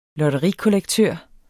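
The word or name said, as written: LOTTERIKOLLEKTØR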